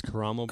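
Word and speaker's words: Karamo: Bobby Burke, Caramo Brown. I'm sorry, from Queer Eye. I believe it's Caramo Brown. – Caramo Brown. My bad if I've said that wrong.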